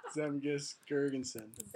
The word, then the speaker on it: Zemgus Girgensons: How do you say his name? – Zemgus Girgensons.